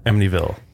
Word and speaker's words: Amityville: Amityville. – Amityville. It's like a silent T, kind of.